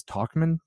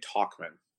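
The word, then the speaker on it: Tauchman: Talkman. – Like, we're talking. Talkman, I thought so. – Like Walkman as a kid, Talkman. – Ah, okay. That makes sense. So yeah. So that means Talkman's job is secure for now.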